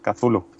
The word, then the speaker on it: Cthulhu: Pues realmente no deberíamos poderlo pronunciar los humanos, Cthulhu. – Ah, Cthulhu. – No tenemos realmente las cuerdas vocales ni el sistema fónico como para poderlo reproducir. – Son los grandes sectarios, ¿no? Los muy metidos en el tema. – Los muy metidos en el tema y los que tengan más de 7 lenguas pueden llegar a pronunciarlo. Pero vale. El resto podemos llamarlo Cthulhu, Tulu o como bien.